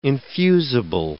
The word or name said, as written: infusible